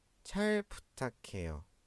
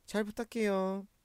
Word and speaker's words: 잘 부탁해요: یا با هم تلفظش کنیم، چای پوتاکیو، چای پوتاکیو، چای پوتاکیو.